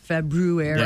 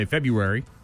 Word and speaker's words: February: There's an R in there. February. – Yeah, right. Yeah. Like when Wednesday, you know, you pronounce it phonetically and you sound like an idiot. Most of us just forego that first R and just say February.